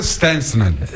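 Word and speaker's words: Szczęsny: Bueno, pues Chesney es nuestro parador de hoy, ¿eh? – Pero bueno. – Se dice Stensnen. La pronunciación dicen los polacos que es Stensnen.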